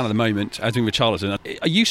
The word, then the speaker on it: Richarlison: He pronounces it Richarlison, apparently that's how you say it in Brazil, Richarlison.